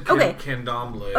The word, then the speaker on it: Candomblé: Candomblé, is that what you said? Yeah. – Yeah, I said candomblé. – Okay, so it's... Yeah, there's no... – Candomblé. – Awesome. So you got it right.